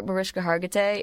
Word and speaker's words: Mariska Hargitay: I remember Mariska Hargitay, is that how you say her name? – Mm-hmm.